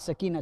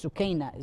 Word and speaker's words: Sukaina: It's not Sakina, it's Sukaina is the right Arabic correct pronunciation in Arabic.